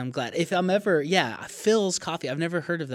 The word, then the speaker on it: Philz: And Phil's is spelled P-H-I-L-Z. – Oh, I see. Right. Okay. – Phil's.